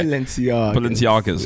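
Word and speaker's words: Balenciaga: Am I pronouncing it right? – You are. – Okay. – Balenciagas.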